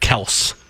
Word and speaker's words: Kelce: Because Jason Kelce says, pronounce it Kels.